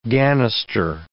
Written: gannister

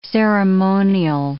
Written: ceremonial

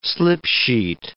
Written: slip-sheet

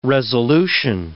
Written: resolution

